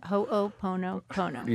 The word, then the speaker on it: Ho'oponopono: Ho'oponopono? – Ho'oponopono.